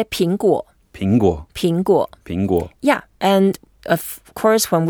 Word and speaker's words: 苹果: 苹果. Yeah, there are only two words, and 果 means the fruit. And 苹, that's the description of this type of fruit. 苹果.